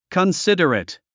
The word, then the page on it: considerate: kənsídərət; コンシダレト